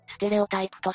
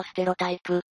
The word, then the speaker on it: ステレオタイプ: というわけで、今回は人物のテンプレであるステレオタイプとステロタイプってことでやっていきます。これよくどっちの発音が正しいとかって話になりますが、どっちでもいいです。英語かフランス語か程度の違いしかないんで。